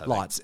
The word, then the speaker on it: Lotz: Is it Lotz or loads? I forget how you say it. But Lotz. Lotz.